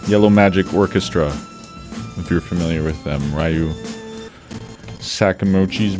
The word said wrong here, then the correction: Ryuichi Sakamoto: band, if I'm pronouncing that correctly. Probably not. Sorry. Ryuichi Sakamoto. My God. Must be the end of the day on a Monday. Ryuichi Sakamoto. That's what I was going for with Yellow Magic Orchestra.